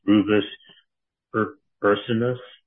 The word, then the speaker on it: Rubus ursinus: Its pronunciation was Rubus ursinus.